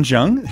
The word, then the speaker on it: Jung: And I also want to thank Sean Young, chaplain of the Valley View Cancer Center. Mm-hmm. And do you say Young? – Jung. – Dang it. – Hard J.